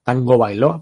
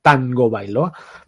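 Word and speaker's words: Tagovailoa: este, hace poco me enteré que él, el hawaiano se, pronuncia de una forma distinta, no es Tango Bailó, así, sino dicho por él mismo, es Tango Bailoa.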